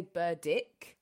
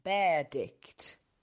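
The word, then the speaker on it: Berdych: I've also got Thomas Burditch, which, you know, that's how I say it, Bert Thomas Burditch. Some people say Burdick. Apparently, the correct way to say it is Tomash Berdicht.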